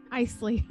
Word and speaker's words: Icle: It's I-C-L-E, Eichel? – I-C-L-E? – It makes me want to say icicle, but that's not right. – I-C-L-E. Isley.